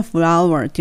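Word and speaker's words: flour: ちなみにこれはね、お花屋さん表してるんですけれども、小麦粉のフラワーっていうの全く同じ発音です。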